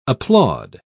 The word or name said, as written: applaud